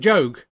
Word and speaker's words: Job: Is that how you pronounce it? Job. Yeah, you pronounce it Job.